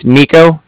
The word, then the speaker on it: Niko: Yeah, so it's my name's Nico. That's spelled N-I-K-O. – Okay. – And then the Bank of Cardiff is spelled C-A-R, D as in dog, I-F-F as in Frank.